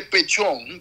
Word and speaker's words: Petión: Entonces, hubo una reunión entre Pechón, que se escribe Petión, y Simón Bolívar, por ahí por 1813, 14, creo que en los tiempos del Congreso de Angostura.